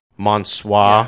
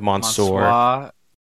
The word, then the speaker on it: Mansoor: Mace has a new pronunciation, Mansoir, yes, instead of Mansoor. Or something.